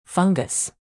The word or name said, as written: fungus